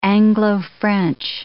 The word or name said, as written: Anglo-French